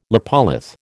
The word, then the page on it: lopolith: American English